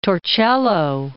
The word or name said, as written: Torcello